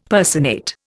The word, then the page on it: personate: Indian